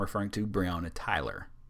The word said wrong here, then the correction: Taylor: or Taylor.